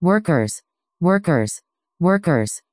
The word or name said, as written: workers